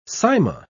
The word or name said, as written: Sima